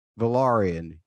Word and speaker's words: Velaryon: This is how I'm going to do the Valyrian pronunciation. I'm going to think, okay, Corlys Velaryon is a sea snake. That means he's kind of like a pirate. Which makes me go R. So I say Velaryon.